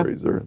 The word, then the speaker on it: Fraser: How do you say it now? – Fraser.